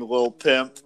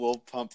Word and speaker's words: Lil Pump: But I just still can't get over Donald Trump saying Lil Pimp. Lil Pimp. You call Lil Pump Lil Pimp. His name rhymes with Trump. Pump and Trump.